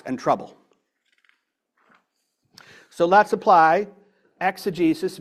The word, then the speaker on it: exegesis: is an exegesis, which is a Greek word that means to draw out the original meaning.